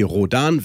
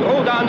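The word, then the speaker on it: Rhodan: Der Nachname von Perry Rodan wird nämlich deutsch ausgesprochen und nicht englisch.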